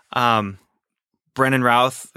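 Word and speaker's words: Brandon Routh: Brennan Routh, is that how you say his name? – Yeah.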